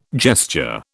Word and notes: gesture: British English

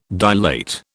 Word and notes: dilate: British English

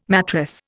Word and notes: mattress: Indian

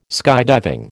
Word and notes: skydiving: American English

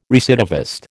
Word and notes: recidivist: American English

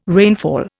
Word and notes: rainfall: Indian

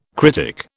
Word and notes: critic: British English

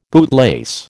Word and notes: bootlace: American English

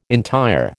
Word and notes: entire: American English

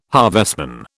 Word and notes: harvestman: British English

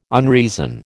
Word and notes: unreason: American English

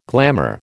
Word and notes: glamour: American English

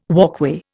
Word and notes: walkway: Indian

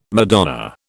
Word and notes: Madonna: British English

